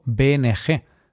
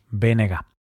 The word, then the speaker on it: BNG: Por cierto, cuando pronuncio BNG, Bloque Nacionalista Galego, no digo BNG, porque la letra G en gallego se pronuncia así, GA, y así es como se le llama al partido, BNG.